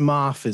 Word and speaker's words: Moff: So it's pronounced Moff, is it? – Moff, yeah. – I think I said Dan Maff, like an absolute. – Oh, yeah, Moff. Yeah, Moff.